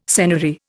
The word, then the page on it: senary: Indian